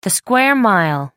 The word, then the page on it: square mile: American English